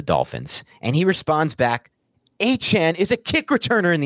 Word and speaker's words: Achane: It's Achane. That's Achane. – So everyone says Achane, including the Dolphins media guide. Achane is how he wants it pronounced, according to Adam Schefter.